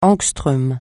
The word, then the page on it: angström: francais